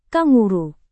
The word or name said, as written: kanguru